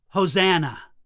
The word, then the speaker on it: Hosanna: And those words in verse 25, "Save now," in Hebrew, you could pronounce it Hosanna, which is exactly what the crowd at the triumphal entry cried out.